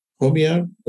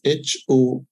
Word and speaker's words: Homiyar: They can go to LinkedIn and find for my name, and my name is spelled as Homiyar. That's Homiyar.